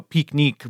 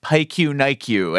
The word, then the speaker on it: pique-nique: I've always really, I like the French, you know, pique-nique, but had a student in my French class when I was young who pronounced it pique-nique-you, and that's, I still talk about, yes, it's time to go on un pique-nique-you.